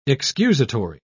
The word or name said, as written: excusatory